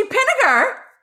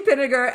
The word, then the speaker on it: Pinniger: It's Ruchi Pinniger. Rhymes with vinegar, but sweeter. That's what she just told me. So it's Ruchi Pinniger, and Ruchi is the CEO and founder of Watch Her Prosper.